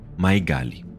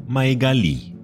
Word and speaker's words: Maegali: Maigali. Alguns falam Maegali, outros Maegali. A pronúncia varia.